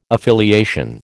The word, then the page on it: affiliation: American English